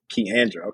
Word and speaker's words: Kiandra: Did I say that right? – Keandra.